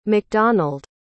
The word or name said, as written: mcdonald